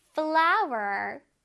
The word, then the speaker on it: flour: 그다음에 flower, flower, 밀가루라는 뜻이에요, 그쵸? 쌤이 아까 말했듯이 꽃 flower랑 발음이 동일합니다. 하지만 스펠링이 다른 거꼭 기억하자, 얘들아.